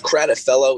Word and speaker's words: Kratofilow: It's Kratofilow.